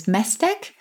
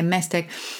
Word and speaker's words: Maesteg: He lives in Cardiff, but his partner comes from a small town in the valleys called, how to pronounce this, Mesteg? M-A-E-S-T-E-G. Okay, Mesteg.